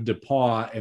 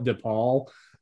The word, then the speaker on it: DePauw: But I also had the pronunciation of DePauw in the next two syllables to focus on. – To not call it DePaul.